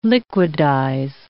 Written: liquidize

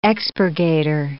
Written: expurgator

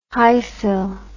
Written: hyfil

